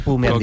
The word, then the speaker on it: Bu: Yun Chaukete Bu. – Me han dicho que se pronuncia Pu.